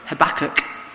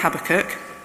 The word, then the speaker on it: Habakkuk: A little bit about Habakkuk, or if you've watched that video that was linked in the email Christine sent, Habakkuk is how they say it in America. But we'll stick with Habakkuk.